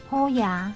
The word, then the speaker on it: 虎爷: 那虎爷的台语怎么念？ – 虎爷。 – 对。跟我们的好爷啊是谐音，所以拜虎爷通常是求财的。